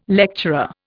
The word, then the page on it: lecturer: Indian